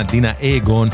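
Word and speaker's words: Dina Ögon: סלקר חדש של דינה אוגון, כותבים את שמם דינה אוגון, זה לא שם של בחורה ישראלית, זו להקה שוודית, מבטאים את שמה דינה אוגון,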